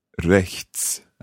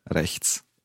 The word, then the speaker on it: rechts: It's quite tricky, the sound at the end there. There's a ch, a t, and a s. Rechts. – Rechts.